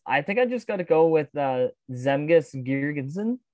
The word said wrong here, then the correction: Girgensons: Uh, Girgensons, I think that's how you pronounce it.